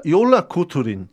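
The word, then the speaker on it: Jólakötturinn: Øh, nu prøver jeg simpelthen at udtale det her rigtigt, fordi øh, for, på islandsk. Jola, Jola Kuturin.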